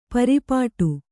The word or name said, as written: ಪರ್ಪಟಿ